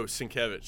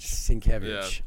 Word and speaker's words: Sienkiewicz: Was it Sykovitz, or how do you pronounce that guy's name? Oh, Sienkiewicz. – Sienkiewicz.